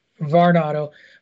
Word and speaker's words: Varnado: But the former Sasha Banks, Mercedes, I can't pronounce her, is it Varnado? – Varnado has ventured into acting.